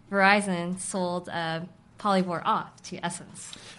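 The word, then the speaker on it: SSENSE: That's S-S-E-N-S-E. – And we think it's pronounced Essence. – Yeah, Essence. – Not Sense, like I thought it was originally.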